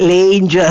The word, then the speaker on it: Lange: Dunque, la mostra si intitola semplicemente Dorothea Lange, Lange, a seconda della pronuncia che si vuol dare al Certo. – nome per le origini, perché era americana ma era di origini nord-europee.